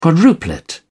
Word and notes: quadruplet: British English

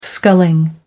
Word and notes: sculling: American English

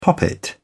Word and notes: poppet: British English

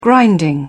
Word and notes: grinding: British English

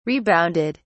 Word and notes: rebounded: British English

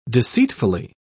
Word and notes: deceitfully: British English

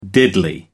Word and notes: diddly: American English

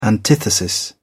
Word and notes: antithesis: British English

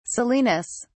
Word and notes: salinas: British English

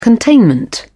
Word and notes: containment: British English

